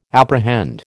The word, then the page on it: apprehend: American English